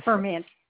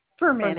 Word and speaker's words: Ferment: Ferment. Ferment. Ferment. Just Ferment. – Like homebrew, sour grapes. Yeah, ferment.